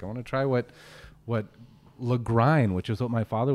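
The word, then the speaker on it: Lagrein: We were always told it rhymes with wine, Lagrine.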